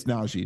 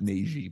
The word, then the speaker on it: Najee: It's Najee, not Najee, by the way.